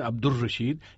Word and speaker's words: عبد الرشید: عبد الرشید کہنا بھی بالکل غلط ہے، اس نام کا صحیح تلفظ ہے عبد الرشید، یعنی اس میں لام کی آواز نہیں آنی چاہیے۔ جمیل یوسف زئی مزید لکھتے ہیں، آج کا سوال ایک اچھا معلوماتی سلسلہ تھا، آپ نے بند کیوں کر دیا؟